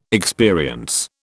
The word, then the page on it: experience: British English